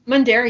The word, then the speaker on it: Mundare: But looking at the Mundare, am I saying that right? – Mundare, yep.